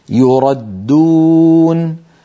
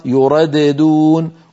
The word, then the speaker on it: يردون: مثال الخامس يردون، يردون الدال المشددة، لو نطقنا الدال الأولى مقلقلة لصارت يرددون، ولا يفعل هذا أبدا أحد أبدا، لا من القراء ولا من العرب.